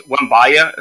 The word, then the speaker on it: Wembanyama: It's Wembanyama. Is how you say it.